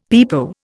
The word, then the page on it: pepo: Indian